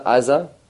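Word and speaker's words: Aza (עזה): And going a little further back, so it's the Gaza, which is actually why, translated wise, it's Gaza, even though in Israel it's Aza.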